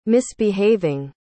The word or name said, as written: misbehaving